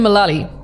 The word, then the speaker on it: Mullally: It's Megan Mullally.